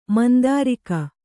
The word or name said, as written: ಮಂದಾರಿಕ